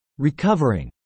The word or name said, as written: recovering